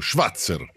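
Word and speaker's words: Schwazer: Buonasera, allora io vorrei parlare in merito a Schwarzer, il marciatore alto tedesco. – L'aveva detto bene, poi si è rovinato da solo. – Schwarzer si chiama, sì.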